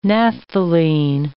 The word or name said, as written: naphthalene